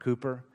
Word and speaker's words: Cowper: It looks like Cowper, but it's pronounced Cooper.